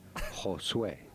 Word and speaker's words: Josué: In Spanish, it would be said as Josué.